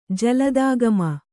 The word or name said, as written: ಜಲದಾಗಮ